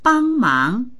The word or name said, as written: bang1mang2